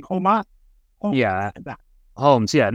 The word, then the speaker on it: Holmes: But here, that that name's pronounced Holmes, like kind of like the home you live in.